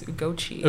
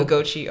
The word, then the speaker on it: Ugochi: like, I don't care if it's that, but like, it's either Ugochi or Ugochi, but like okay. Nigerian way is Ugochi. And as I'm getting older, I like just okay. pronounce like the original. I embrace the original.